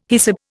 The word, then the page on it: hyssop: Indian